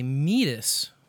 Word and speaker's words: Midas: It's like you take the mead from mutton and mead, you put it with us, like you and me, mead, us, Midas. That's a little quick pronunciation guide.